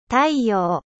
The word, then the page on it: soleil: French